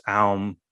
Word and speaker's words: Aum: I think it's no coincidence that it sounds like Amen, but there are other traditions like the Buddhist Aum or Om, yes, which yes. is very similar to it as well. The important thing in that is that you have the three syllables A-U-M, but also there's a fourth syllable, which is the most important one of all, and that is silence.